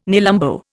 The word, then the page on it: nelumbo: Indian